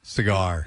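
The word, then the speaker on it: Seger: We're going to go through the whole Seeger catalog. – We might have to, Press, but, you know. – S-E-G-E. – I know. Yeah. I spelled it wrong. – Spell it. Cigar.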